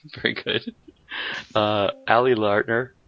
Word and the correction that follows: Larter: Larter, and Sean Roberts, who I can't believe keeps showing up as Wesker.